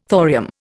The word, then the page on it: thorium: Indian